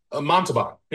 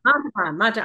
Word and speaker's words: Montalban: How do you say his last name? Ricardo Montalban, yeah. – Montalban. I was gonna say Mazatlan.